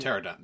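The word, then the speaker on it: Pteranodon: And Pterodon, the P is silent. It's like pterodactyl. It starts with P, ends with T. You pronounce the T.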